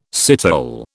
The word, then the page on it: citole: British English